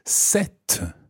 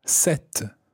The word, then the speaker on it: cette: Now, the feminine version of this is cet. And it's spelled C-E-double-T-E. Cet.